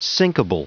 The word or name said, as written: sinkable